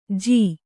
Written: ಝ

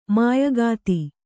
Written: ಮಾಯಗಾತಿ

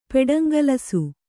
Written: ಪೆಡಂಗಲಸು

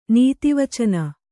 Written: ನೀತಿವಚನ

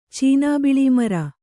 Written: ಚೀನಾಬಿಳೀಮರ

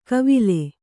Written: ಗಾವಿಲೆ

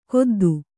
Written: ಗುದ್ದಿ

